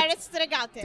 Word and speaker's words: Terre Stregate: And again, it's Terre Stregate, correct? Terre Stregate.